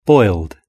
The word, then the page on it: boiled: British English